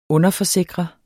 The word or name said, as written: underforsikre